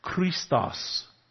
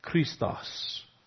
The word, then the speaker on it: Christos: In Greek, it's pronounced Christos. Christos, where we get the word Christ.